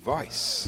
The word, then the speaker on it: Weisz: Rachel, the fine actress, Rachel Weisz.